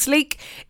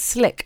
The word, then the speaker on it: Slique: Slick is spelled S-L-I-Q-U-E. You might have heard me saying Sleek J Adams. It's not Sleek, it's Slick. I have it on good authority.